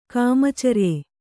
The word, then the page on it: ಕಾಮಚರ್ಯೆ: Kannada